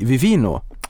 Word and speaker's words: Vivino: Men i februari så investerade ni 70 eh, miljoner dollar i Vivino. Ja, det stämmer. Eh, uttalar jag det rätt då? Ja. Perfekt. Plötsligt händer det.